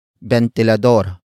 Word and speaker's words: bentilador: Ventilador. Ventilador. Ventilador is spelled as B-E-N-T-I-L-A-D-O-R.